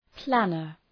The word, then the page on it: planner: ‘plænər